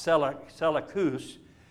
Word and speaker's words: Seleucus: Seleucus, you'll also hear it pronounced Seleucus, was another Roman general that took over in Syria, just north of Palestine.